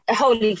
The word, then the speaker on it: Holi: I think one of the earliest ones that I remember seeing you take part in is the Holi Festival. – Yeah. – Have I pronounced that right? – Yeah, Holy Festival. Holy. Yeah.